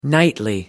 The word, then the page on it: nightly: American English